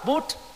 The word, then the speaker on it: Buddha: chữ a nằm ở cuối từ đó là a câm không có đọc không có đọc là buddha đọc buddha là đọc theo chữ anh hóa latin hóa còn đọc theo cách phát âm của người ấn độ là bút